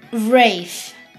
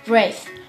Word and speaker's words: Wraith: Und wenn ihr euch fragt, wer mein Lieblingslegend ist, es ist Wraith. Ja, so spricht man die aus. Wraith. Und ja, dann gehen wir rüber zu Minecraft.